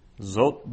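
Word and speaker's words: zot (זאת): So zot is difficult because that aleph is totally quiescent, so it has no pronunciation in and of itself. It's in terms of how you pronounce it, it's as if it wasn't there.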